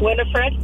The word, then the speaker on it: Winifred: Winifred? – Yeah!